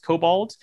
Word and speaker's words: kobold: which is the kobold, I believe is how it's pronounced. Kobold, kobold.